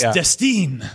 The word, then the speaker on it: Destin: It's pronounced Destine. – Destine. – Well, now I can say it's like Destiny without the Y, but then people write D-E-S-T-I-N-E. And I'm like, no! There's no last letter.